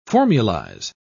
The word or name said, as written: formulize